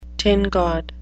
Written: tin god

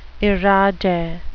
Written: irade

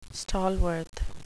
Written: stalworth

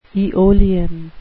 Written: aeolian

